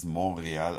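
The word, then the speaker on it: Montréal: Und er meinte eben, dass es Montreal ausgesprochen wird. Das heißt, der Königs Königsberg. – Königlicher Berg. Mont Royal. Letzten Endes, wenn man es genau nimmt, erklärt sich schon von selbst.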